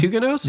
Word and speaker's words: Huguenots: You do too, French Huguenots? – Yeah. – Really? – You even pronounce it right.